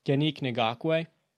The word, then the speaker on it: Yannick Ngakoue: They acquire Yannick Ngakoue. Did I pronounce that right? – Yes, you did.